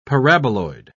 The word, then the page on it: paraboloid: British English